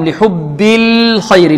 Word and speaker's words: لحب الخير: لحب الخير، اللام هنا مرققة والخاء مفخمة. لماذا فخمناها؟ لأنها من حروف الاستعلاء.